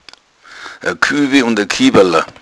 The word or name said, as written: kuebel